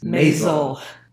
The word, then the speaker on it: Maisel: The first is The Marvelous Mrs. Maisel. Rhymes with Hazel.